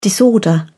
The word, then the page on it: disorder: British English